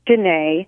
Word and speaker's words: Dinneh: My middle name is Dinneh, so D I N N E H We'll put it in the show notes.